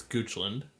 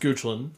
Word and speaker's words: Goochland: It sits undisturbed on a bluff overlooking the James River, about 15 miles west of Richmond, in Goochland County. – It's Goochland. – Yeah. – It's pronounced Goochland.